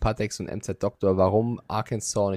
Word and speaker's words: Arkansas: Die offizielle Sprach Aussprache äh, heißt Arkansas, denn 1881 hat man das gesetzlich geregelt, nachdem sich zwei Senatoren über die Aussprache ihres Bundesstaates stritten.